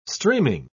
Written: streaming

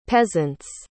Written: peasants

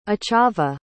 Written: achava

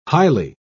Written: highly

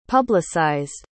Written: publicized

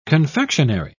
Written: confectionery

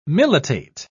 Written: militate

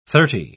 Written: thirty

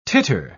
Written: titter

